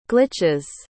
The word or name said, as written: glitch's